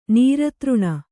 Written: ನಿರಾದರಣೆ

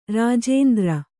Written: ರಾಜೇಂದ್ರ

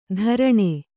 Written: ಧಾರಣೆ

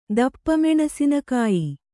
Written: ದಪ್ಪಮೆಣಸಿನಕಾಯಿ

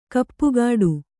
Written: ಕಾಪುಗಾಡು

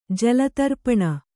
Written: ಜಲತರ್ಪಣ

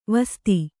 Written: ವಸ್ತ

